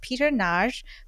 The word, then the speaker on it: Peter Nagy: So today in our virtual studio, we have Peter Nagy, who is a media scholar working at Arizona State University as a lecturer.